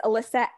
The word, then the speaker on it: Elissa: Um, so my Instagram is Elissa M Williams.